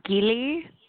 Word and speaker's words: Gilli: And what is your, and it's an Israeli name, right? What's the pronunciation? – Yeah, so I'm half Israeli. My name is pronounced Gilli, but it's really tough for a lot of Americans to say it, especially because of that subpar Ben Affleck film, Gilli, that came out like way back when.